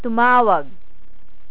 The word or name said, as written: tumawag